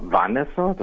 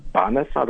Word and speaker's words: Vanessa: Ja, Vanessa, also wir sagen auf Deutsch ja immer Vanessa, das heißt Vanessa, das im Spanischen wird hier das V als B ausgesprochen.